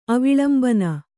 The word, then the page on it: ಅವಿಲಂಬನ: avilambana